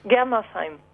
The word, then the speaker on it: Germersheim: Et t'habites à Gemmersheim, je prononce ouais, ça. C'est presque ça. – Vas-y, dis-le bien, toi. – Germersheim. – Voilà, Germersheim.